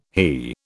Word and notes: HE: British English

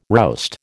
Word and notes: roust: American English

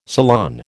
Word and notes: salon: American English

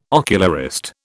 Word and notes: ocularist: British English

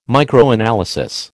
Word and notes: Microanalysis: American English

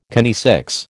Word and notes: kinesics: American English

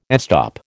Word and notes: estop: American English

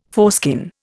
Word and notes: foreskin: Indian

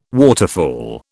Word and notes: waterfall: British English

